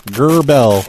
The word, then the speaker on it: Gerbil: Well, no, it's Roy Zurbel. You asked how to spell that. – Okay, how do you spell Zurbel? – Oh, G-E-R-B-I-L. – G-U-R-B-I-L. – Yep. Gerbel.